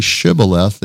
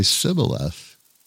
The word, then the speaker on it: shibboleth: And if they tried to say shibboleth, they would say sibboleth, you know. And they kill them because they couldn't say it right.